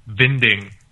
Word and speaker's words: Winding: Nicholas Winding... Refn. – Actually, I just have to step in here. It's actually pronounced Nicholas Vinding Refn.